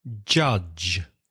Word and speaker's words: judge: Another profession, judge, J-U-D-G-E. That's quite difficult for Spanish speakers, isn't it? Because of the vowel sound, judge.